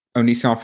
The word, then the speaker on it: Onision: Onision.